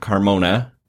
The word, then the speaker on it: Carmona: So as you mentioned, on the Democratic side for the Senate, we have Dr. Richard Carmona.